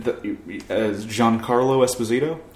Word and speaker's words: Giancarlo Esposito: And he did Giancarlo Esposito? – Yes. I think that's it. That's how you say it.